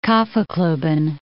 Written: Kaffeklubben

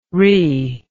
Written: ree

